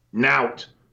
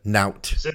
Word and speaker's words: nowt: and it's spelled N-O-W-T. – Nout. It's a Yorkshire word. – Nout.